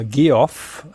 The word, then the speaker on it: Geoff: Aber jedenfalls der amerikanische Vorname Geoff wird einfach ausgesprochen wie Jeff, weil es einfach eine Form von Jeff ist.